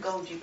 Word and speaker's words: Goji: Goji berries. – Dark chocolate. Oh, okay. Goji is G-O-J-I. I think that's how you say it.